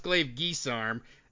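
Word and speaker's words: Glaive-guisarme: Okay, first of all, it's pronounced Glaive Guisarm.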